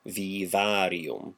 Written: vīvārium

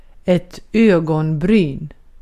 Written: ögonbryn

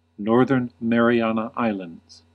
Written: Northern Mariana Islands

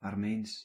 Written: Armeens

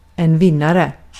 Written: vinnare

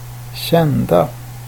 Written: kända

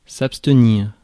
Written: abstenir

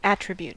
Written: attribute

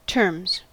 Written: terms

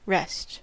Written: rest

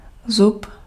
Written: zub

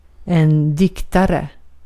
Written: diktare

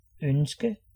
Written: souhait

